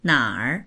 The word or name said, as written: nar3